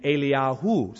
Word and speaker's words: Eliyahu: That's because we mispronounce the names. It's actually Elisha and Eliyahu. So if you want to learn to pronounce it that way, it'll save confusion. But We don't do that. So let's just say Elisha, E-L-I-S-H-A.